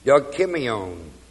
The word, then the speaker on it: dokimion: It is the Greek word dokimion. D-O-K-I-M-I-O-N. Remember experience I gave you a little while ago as dokime, D-O-K-I-M, long E.